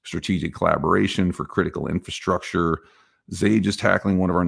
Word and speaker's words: Xage: Matter of fact, just today, there was another investment for $20 million around ZT for a company called Zage. I believe that's how you say it. X-A-G-E. Is it X-Age? I think it's Zage. Sounds cooler to say Zage.